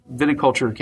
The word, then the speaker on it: Viticulture: Am I saying that right? Right. – Viticulture. Correct. Viticulture.